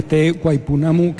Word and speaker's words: Te Waipounamu: Pues mira, Te Waipounamu,